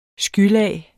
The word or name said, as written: skylag